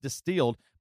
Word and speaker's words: DSTLD: It's D-S-T-L-D, and it's pronounced Distilled, but they really kind of revolutionized the fashion industry.